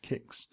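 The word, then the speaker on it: Qix: K-Q-I-X, pronounced Kix.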